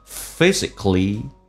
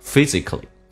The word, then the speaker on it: physically: All right, being physically, 那个 k 跟 l 在一起的时候，k 可以发，可以不发。有的人会不发的时候到那边。气流顿一下，然后再到 ly，这个发音叫 physically。如果你要发的话，很显然没有停顿的感觉，叫 physically。 OK，这两种都是对的。